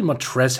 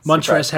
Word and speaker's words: Montrezl: It's actually Montrezl Harrell, I figure out. I've always pronounced the L. – Yeah. – That's an adjustment to me. Because on broadcast sometimes, announcers actually do the same mistake as I do and pronounce L.